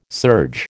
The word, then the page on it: serge: American English